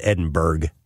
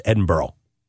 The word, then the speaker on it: Edinburgh: Edinburgh, and you do pronounce it Burgh, even though it's B-U-R-G-H. I learned that. I thought it was Edinburgh. No, it's Edinburgh. That's how you pronounce it.